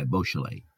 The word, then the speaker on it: Boisjoly: It's spelt B-O-I-S-J-O-L-Y, Beausoleil.